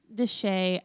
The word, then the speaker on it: de Chelly: And Canyon de Chelly, I can't want to say Del. It's De Chelly. – Yeah, Canyon de Chelly. – De Chelly. – But it looks like Chelly. – It does. It's spelled like Canyon de Chelly. Chelly. Yeah. Canyon de Chelly, but it's pronounced Canyon de Chelly.